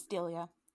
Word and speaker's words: Delia: or does she say pronounce it some other way? – It's Delia.